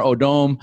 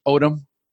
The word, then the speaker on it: Odom: Am I pronouncing that right? Odom? – Odom. It gave him a nice fancy twist. I kind of like it. It sounded a little Odom. French. – Yeah, it does sound a little, as I was Odom. Reading it, I go, nobody pronounces it like that. They all say Lamar Odom.